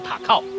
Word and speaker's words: 高雄: 啊，竹子呢，原住民语叫做塔靠，哦，后来汉人就称这里叫做打狗山。日本人也取它的谐音叫高雄塔靠，因为高雄这两个字啊，日语发音就是塔靠。